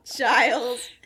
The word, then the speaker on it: Giles: Giles.